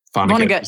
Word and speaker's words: Vonnegut: Vonnegut. Vonnegut is how I say it, yeah.